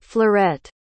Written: fleuret

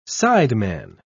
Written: sideman

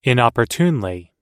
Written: inopportunely

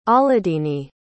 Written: ahladini

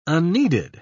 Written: unneeded

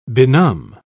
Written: benumb

